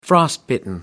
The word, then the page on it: frostbitten: British English